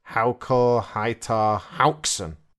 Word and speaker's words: Haukur Heiðar Hauksson: Okay, I'm not that good at Icelandic, but I think you would say Haukur Heiðar Hauksson. – That sounds pretty good. The information I'm looking at says you say it like this. Haukur Heiðar Hauksson. Not sure there's much of a difference there.